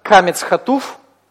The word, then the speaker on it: камец хатуф: Это у нас всегда камец хатуф, который произносится как О, и вместе получается хатев камец, который произносится как сверхкраткий О.